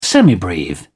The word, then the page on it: semibreve: British English